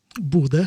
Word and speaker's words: Bodø: Bodø.